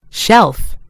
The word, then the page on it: shelf: British English